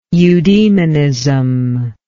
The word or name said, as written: eudemonistically